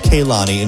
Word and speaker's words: Kehlani: Even though it's spelled that way, her name is Kehlani,